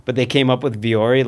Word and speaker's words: Vuori: Viore. I pronounce things strangely, but this is correct. Viore. Like Viore. – V-U-O-R-I. – Yes, but they do have a pronunciation guide, and it's just what I say.